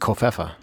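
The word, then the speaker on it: covfefe: believe he said something about Kofifi it's I, I believe it's pronounced Kofefa.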